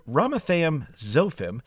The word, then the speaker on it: Ramathaim Zophim: Now, did you notice, if you feel comfortable saying the word Ephraim, then Ramathaim Zophim, not so bad. Rama and then Thaim is very similar to Ephraim if you look at those last three letters.